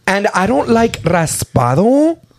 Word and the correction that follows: reposado: Raspado? Is that how you say it? – No, reposado.